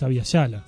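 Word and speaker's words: Abya Yala: ¿Cómo se pronuncia? Por lo que escuchamos en la introducción, es Avia Yala.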